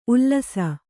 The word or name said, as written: ಉಲಿಸು